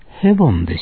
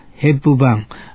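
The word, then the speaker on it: Hepburn: 一般市民に衛生のため作り方を教えたのは医療宣教師ヘボンでした。有名な人です。英語の発音ではヘップバーン、あのオードリーヘップバーンと同じですが、シャボンを流行らせたという親近感があって、ヘボン先生と宣教師なのに親しまれていました。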